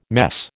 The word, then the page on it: mess: American English